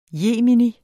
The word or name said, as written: jemini